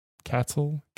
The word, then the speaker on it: Cathal: Is it Cathal? C-A-T-H-A-L? Cathal? Cathal? – Cathal.